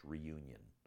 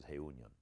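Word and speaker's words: Reunión: And the town in El Salvador is called Reunion, or we would say in English, Reunion. But the right way to say it in Spanish is Reunion.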